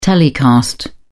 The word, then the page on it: telecast: British English